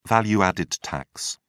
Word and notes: value-added tax: British English

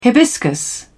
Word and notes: hibiscus: British English

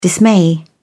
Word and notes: dismay: British English

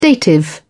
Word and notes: dative: British English

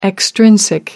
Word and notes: extrinsic: American English

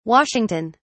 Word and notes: Washington: British English

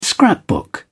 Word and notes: scrapbook: British English

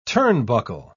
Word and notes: turnbuckle: British English

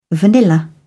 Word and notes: vanilla: British English

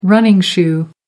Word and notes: running shoe: American English

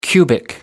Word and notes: cubic: American English

